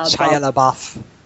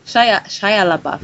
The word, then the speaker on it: Shia LaBeouf: ez a Shia Buff. – Shia LaBeouf. – Shia, Shia LaBeouf.